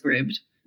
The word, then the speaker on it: Scribd: So first of all, no one knows this, but it's pronounced Scribd.